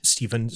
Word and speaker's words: Steven: Uh, you can find me on Twitter at, at Steven Strom, S T E V E N S T R O M.